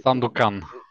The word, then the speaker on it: Sandukan: Men eh, Sand, Sandukan eller hur man uttalar namnet. Eh, Sandukan.